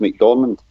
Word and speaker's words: McDormand: Well, Frances McDermott, you know the movie star? She lives there with her husband. – Yeah, you mean Frances McDormand? – McDormand, that's it.